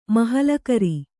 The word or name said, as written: ಮಹಾಲಕರಿ